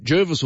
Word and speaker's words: Jervis: The locals say it's Jervis, and we've had quite a few people, even though the GPS will say Jervis and lots of Sydney siders will too. It's the Jervis family from back in the day. That's how they pronounce their name.